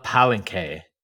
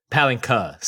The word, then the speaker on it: Palenque: And I want to shout out Ed the Revelator, who is the Silent Spider, guardian of Cirith Ungol, and Johnny Flores, man of Palenque. Palenque, sorry. I will work on my pronunciations as we go forward.